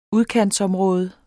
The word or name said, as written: udkantsområde